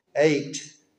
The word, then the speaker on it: eitt: Their word for the number one is spelled E-I-T-T. And guess what it sounds like when you say it? Eight.